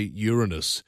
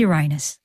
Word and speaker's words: Uranus: It is Uranus. You can't say Uranus. That's like the emphasis on the wrong syllable.